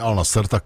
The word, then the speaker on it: Al Nassr: Ale tak Al Nassr se vyslovuje normálně Al Nassr, tak to bude...